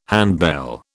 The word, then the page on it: handbell: British English